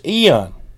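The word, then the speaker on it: Ian: Now, I was saying ion. I actually said ion. I actually said ion on the news, Power 107 this morning. But the actual pronunciation is eon.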